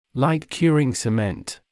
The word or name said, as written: light curing cement